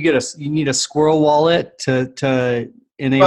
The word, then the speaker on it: SQRL: which is SQRL. It's pronounced Squirrel.